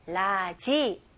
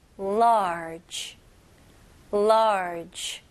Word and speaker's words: large: 제대로 영어로 발음하실 거면 large 이름절로 하셔야 돼요. 오케이, 이거는 많은 연습 통해서 가능합니다. 오케이, 라지 아니고요. large, large. 그리고 마지막으로 J 발음으로 끝내셔야 돼요. 그래서 모음 소리 없이 large.